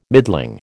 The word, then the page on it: middling: American English